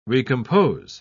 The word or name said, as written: recompose